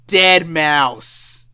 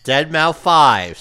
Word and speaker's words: Deadmau5: Dead Mouse. – Dead Mouse 5, say it right.